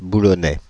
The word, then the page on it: boulonnais: France (Île-de-France); /bu.lɔ.nɛ/